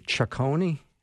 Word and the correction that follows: Chaconne: Okay. I'm over my skis on Bach composition, but yeah. – It's Chaconne.